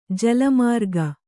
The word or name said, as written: ಜಾಳಮಾರ್ಗ